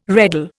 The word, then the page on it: reddle: Indian